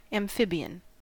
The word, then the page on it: amphibian: American English